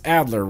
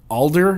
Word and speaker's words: Adler: A-D-L-E-R. That's Adler, right? I think I said Alder before, and that's completely wrong, but it is Adler.